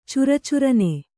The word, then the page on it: ಚರಚರನೆ: cara carane